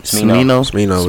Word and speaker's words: Smino: Smino.